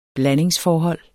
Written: blandingsforhold

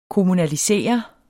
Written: kommunalisere

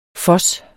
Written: FOSSEN

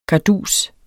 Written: kardus

